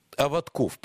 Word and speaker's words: Аватков: Владимир, я всегда неправильно делаю ударение вашей фамилии. Аватков, правильно, правильно? – Правильно.